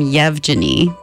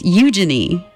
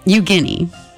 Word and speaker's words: Yevgeny: And so the protagonist in this story, I've been calling him Yevgeny because that's how his name is spelled, Y-E-V-G-E-N-Y, Yevgeny. So I've been saying it wrong, though. It's Eugeny. Eugeny, I'm sorry. Eugeny.